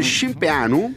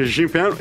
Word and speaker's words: Cimpeanu: Bene, perché parleremo della Canalis, ma la cosa più difficile è pronunciare il nome del suo nuovo fidanzato, oserei dire del suo nuovo Toy Boys. Ah, man. Eh sì, perché lui si chiama Georgian Scimpeanu.